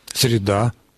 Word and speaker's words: среда: А вот Тамара Алексеевна еще спрашивает, как правильно говорить, среда или среда? – Среда. – Но вопрос, видимо, по средам, и это считается единственно правильным в современном русском языке. То есть у него стоит вторая, там, третья лекция по средам. – Если речь идет о дне недели. А если речь идет об окружающей среде, то, конечно, ударение противоположное, на корне.